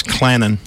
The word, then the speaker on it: Clanton: You said it wrong. That's Clannon. – Clanton. – Yeah, no T in Clanton.